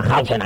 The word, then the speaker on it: Hausen: Y sí, y Hausen es, pues, como suena Hausen ahí con una H. Como Hausen. – Hausen. – H-A-U-S-E-N.